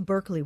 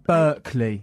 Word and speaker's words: Berkeley: Well, I went to Berkeley when I was young. That's how you say it. – Berkeley.